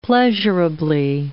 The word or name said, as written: pleasurably